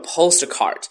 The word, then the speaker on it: postcard: 啊、在这里呢有这样的一个单词，我们需要去注意一下它的发音，叫做 postcard。当两个爆破音都连在一起的时候啊，通常是前者失去爆破音。那么这个单词当中呢，哪两个这个音是爆破的呢？